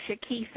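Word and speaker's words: Chakise: On my page, my name is Shaquise Encourages Peace on your page.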